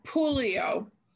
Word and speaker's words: Pouliot: Certainly, Pouliot. – Pouliot. – Yes, it's French.